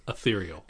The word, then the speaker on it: ethereal: Ethereal. – It came to me after I butchered it 3 times and I had already moved on.